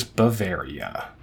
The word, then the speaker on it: Bavaria: And he gets that correct with what is Bavaria. – Mm-hmm. – Which rhymes with area.